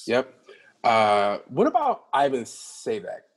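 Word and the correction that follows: Ivanišević: Goran Ivanisevic.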